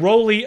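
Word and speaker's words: Rollei: Rolly, by the way, that's how we're supposed to pronounce it.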